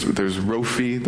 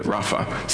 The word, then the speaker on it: Rapha: Now I have to apologize for many of you because you've learned a different pronunciation. There's Rofi, there's Rafi, there's Rafa, there's Rofa. Lots of different people will pronounce it different ways. So I just came up to the school where I learned it Rapha.